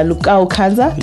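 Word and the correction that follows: Lokua Kanza: Did I say that right? – Lukua Kanza. Legendary.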